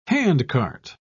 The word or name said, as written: handcart